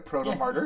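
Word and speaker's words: Protomartyr: Proto Martyr.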